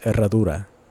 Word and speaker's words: Herradura: It's pronounced Herradura.